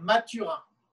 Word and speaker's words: Mathurin: Eu, é Benoit Maturin.